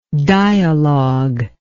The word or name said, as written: dialoguer